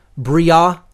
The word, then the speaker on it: Briah: Slightly off on the pronunciations, but I'm not going to, hold it to that, level of specificity. I would say if I were to give the correct pronunciations as closely as I know them to be, it's Asiyah, Yetzirah, Briah, and Atzilut.